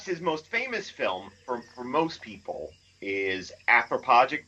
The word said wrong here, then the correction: Anthropophagus: Watch me mispronounce this. – Anthropophagus. – That thing, yes.